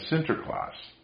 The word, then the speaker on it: Sinterklaas: and of course the Dutch pronounced St. Nicholas as Sinterklaas.